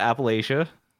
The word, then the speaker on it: Appalachia: You get all pissy when I call it Appalachia.